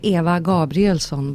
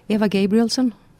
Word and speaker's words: Eva Gabrielsson: In Swedish, you'd say Eva Gabrielsson, but that's the Swedish pronunciation. If I myself would say it in English, I'd say Eva Gabrielsson.